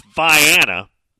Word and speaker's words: Vienna: Cairo, C-A-I-R-O, and we already... We always talk about that one. It's pronounced Cairo. Vienna is pronounced Vienna.